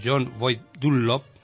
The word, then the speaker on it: John Boyd Dunlop: John Boyd Dunlop, que no sé si he pronunciado bien.